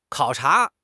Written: 考察

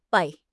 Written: bei4

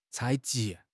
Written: 采集

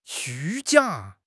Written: 虚假

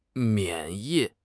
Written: 免疫

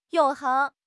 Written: 永恒

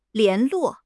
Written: 联络

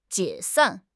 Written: 解散

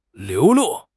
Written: 流露

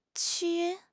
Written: qu1

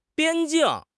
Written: 边境

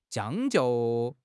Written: 将就

